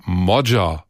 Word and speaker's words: Magyar: Sein Name schreibt sich zwar Péter Magyar mit Y, der Nachname wird aber Modja ausgesprochen. Da sind am Ende doch einige drüber gestolpert.